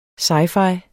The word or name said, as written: sci-fi